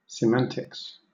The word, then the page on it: semantics: British English